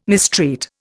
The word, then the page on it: mistreat: Indian